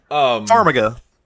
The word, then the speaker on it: Farmiga: Farmiga. – Oh, is it that simple? I thought it was something way more complicated. – Farmiga. – What's up? – I think it's Vera Farmiga.